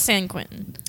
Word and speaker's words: San Quentin: It was San Quentin. – When they go to San Quentin,